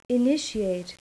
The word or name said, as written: initiate